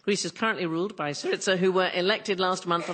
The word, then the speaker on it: Syriza: How do you say this, darling? Is it Syriza? Syriza? – Syriza.